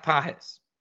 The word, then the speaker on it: Pages: Andy Pages, or as it's spelled, Andy Pages. It's pronounced Pages.